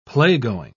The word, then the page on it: playgoing: British English